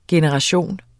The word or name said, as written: GENERATIONERNE